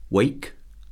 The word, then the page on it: wake: verb